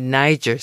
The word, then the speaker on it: niger: If you want to attract goldfinches, well, then you need Niger seed. Some places spell it N Y G E R, some spell it N I G E R.